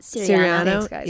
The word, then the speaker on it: Siriano: But I had like, I was wearing like Christian Siriano. Am I saying that wrong? Siriano? – Siriano.